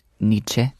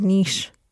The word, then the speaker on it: Nietzsche: Well, you know, that is the famous philosopher Friedrich Nietzsche. There, I said it right. Finally, I always say Nietzsche. Nietzsche. – That was niche.